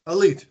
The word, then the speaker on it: Alite: Um, um, and by the way, is his name pronounced Alite or Elite? Because we've always been saying Elite. Elite, that's right.